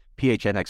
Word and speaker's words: PHNX: It's PHNX Sports. I get on a lot of places that just pronounce it as Phoenix, but we've removed them all. So it's just pronounce the letters.